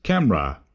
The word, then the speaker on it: CAMRAS: These signals are offered over the internet by means of the Camera Web SDR, that's spelt C-A-M-R-A-S, and as I understand it, it's pronounced in Dutch as Camera, with a silent S, and stands for the C.A.